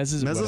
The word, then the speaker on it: Mesozoic: The Mesozoic, I think that's how you pronounce it. Mesozoic? – Mm-hmm. Mesozoic.